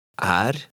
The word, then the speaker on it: Er: Note how the E is pronounced in some short words that end in R. Now you read number 11. – Er.